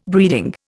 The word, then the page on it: breeding: Indian